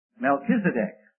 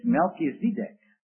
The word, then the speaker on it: Melchizedek: He said, "All of you preachers are alike." He said, "You don't know how to pronounce some of these names. It's Melchizedek." Well, okay. But I think the general acceptance is, and the general usage is, Melchizedek.